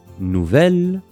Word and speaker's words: nouvel: 学校の新しい先生を紹介します。Placé avant un nom masculin commençant par une voyelle, l'adjectif se prononce comme le féminin nouvelle, mais s'accorde au masculin et s'écrit donc N O U V E L.